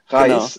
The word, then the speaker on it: Reis: Ludovit Reis.